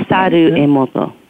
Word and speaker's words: Masaru Emoto: In March of 2004, you began to work as a personal assistant to Dr. How do you pronounce his name? – Masaru Emoto. Mm-hmm. – Masaru. Okay. Masaru Emoto, Dr. Emoto, author of The Hidden Passages of Water.